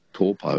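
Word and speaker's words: Taupō: When I was in New Zealand, there was Lake Taupo. Now it's called Taupo. – Taupo, yeah, that's right. It's just the vowel pronunciations are a bit different.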